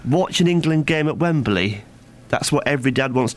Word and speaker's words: Wembley: You add an extra syllable. Wembley. – I never said Wembley. Wembley. – You said Wembley. – Wembley. – You said Wembley, and you know it. It's Wembley, and you always put an extra syllable.